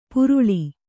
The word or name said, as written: ಪುರುಳಿ